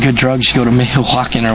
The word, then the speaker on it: Michoacán: He said he's been in one of the Mexican states that the State Department considers dangerous, and I can't pronounce this, M-I-C-H-O-A-C-A-N, Michoacán. It's like their version of Michigan, Michoacán, a few weeks earlier, and the warning is overblown.